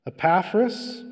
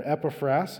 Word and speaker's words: Epaphras: Epaphras, or Epaphras, if you want to say it right,